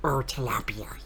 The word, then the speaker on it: tilapia: You'll love the combination of warm, spicy pan-fried tilapia, yes, that's how you pronounce it, yes.